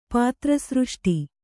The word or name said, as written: ಪಾತ್ರಸೃಷ್ಟಿ